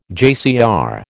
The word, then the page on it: JCR: American English